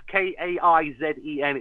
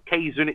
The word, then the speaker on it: Kaizen: if you Please like and share the Kaizen. That's K A I Z E N. It's not Kaizen, it's Kaizen. It means um, for for better in Japanese.